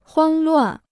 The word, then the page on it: 慌乱: huāng luàn